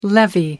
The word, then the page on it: levee: American English